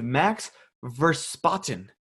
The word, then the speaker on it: Max Verstappen: I don't know what I'm doing, but I think I just like that third-ranked guy, and I'm going with Max Verstappen.